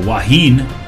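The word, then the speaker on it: Wahine: Waheen of Hawaii. Brennan educated me earlier. This is how you say, that's what they call their women's teams there, the Waheen.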